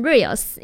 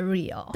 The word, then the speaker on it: Real: 对，没错， Real 这一首这个专辑都一直让我想要念成 Reels、因为呢最近就是 IG 滑太多了、我就一直很常念成它是真实的 Real。 – 对，它不是 R-E-E-L 也不是 R-E-E-L-S，它是 R-E-A-L，真实这样子。